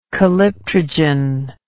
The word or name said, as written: calyptrogen